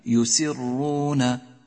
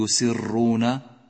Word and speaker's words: يسرون: دقق في حرف الراء من هذه الكلمة يسرون، ولاحظ طريقة نطقك لهذا الحرف، أي إياك أن تكرر الحرف، لا تقول يسرون ولكن يسرون يسرون.